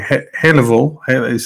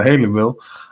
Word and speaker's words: Haleyville: And whether it's, or as you say, Haleyville, say it like Louisville, but Haleyville, whether it's New England, whether it's, Colorado, right?